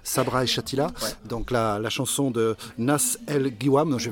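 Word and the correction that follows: Nass El Ghiwane: Nas El Riwan. – Nas El Riwan, voilà. Et quand il y a GH, tu dis Re. Tu fais comme si c'était un R.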